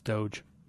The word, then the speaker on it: Doge: It's an internet meme thing. So it's I think it's Doge.